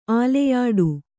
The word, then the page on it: ಆಲೆಯಾಡು: āleyāḍu